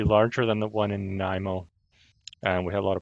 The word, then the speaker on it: Nanaimo: nanaimo nanaimo